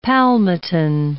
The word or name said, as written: palmitin